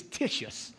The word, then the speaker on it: Titius: See, y'all thought it was Titus, but it's Titius. That's how you got to say his name too.